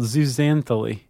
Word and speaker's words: zooxanthellae: It's called zooxanthellae. – You're right. Very nice.